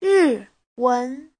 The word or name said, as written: ri4wen2